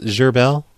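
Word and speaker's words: Gherbille: Yeah, it's Gerbel.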